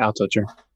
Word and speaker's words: Altucher: I don't know if I'm pronouncing it right. Altucher? Altucher? – Mm-hmm. Altucher.